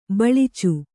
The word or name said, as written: ಬಿಳಿಚ